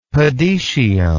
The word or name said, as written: podetium